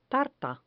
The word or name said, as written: tarta